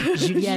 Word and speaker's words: Julia: Julia, avec un A. Julia Pietri.